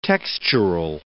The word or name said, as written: textural